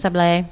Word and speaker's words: Sablé: How would you pronounce that? Petite Sables? – Sablé. – Sablé.